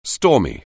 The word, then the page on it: stormy: anglais